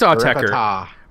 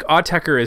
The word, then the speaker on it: Autechre: And then coming in last was Autechers, Try Repite. Maybe. I don't know. That's how you... I think it's Autechre. – Repita. – I think Autechre is how you pronounce the band name.